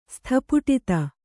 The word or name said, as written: ಸ್ಥಪುಟಿತ